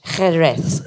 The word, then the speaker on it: Jerez: nous l'appelons sherry, euh, c'est jerez.